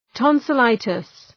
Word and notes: tonsillitis: ,tɒnsə’laıtıs

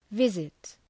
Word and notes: visit: ‘vızıt

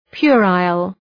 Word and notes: puerile: {'pjʋrəl, 'pjʋraıl}

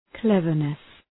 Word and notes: cleverness: 'klevərnıs

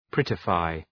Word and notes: prettify: ‘prıtə,faı

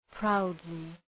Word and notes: proudly: ‘praʋdlı

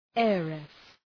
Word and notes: heiress: ‘eərıs

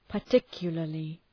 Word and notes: particularly: {pər’tıkjələrlı}

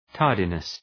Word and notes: tardiness: ‘tɑ:rdınıs

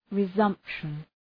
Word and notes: resumption: rı’zʌmpʃən

